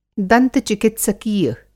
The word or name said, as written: दंत-चिकित्सकीय